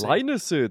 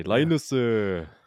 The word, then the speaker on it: Lainusu: Leinusu, that's the way how you say it, as the Koreans would say. Leinusu.